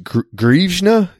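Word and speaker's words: hryvnia: But I believe it's pronounced Grivna.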